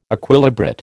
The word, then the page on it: Equilibrate: American English